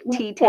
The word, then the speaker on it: Teton: But it's not. It's like T-10.